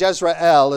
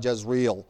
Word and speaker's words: Jezreel: And you don't know much about Jezreel, probably, but Jezreel is probably the correct pronunciation.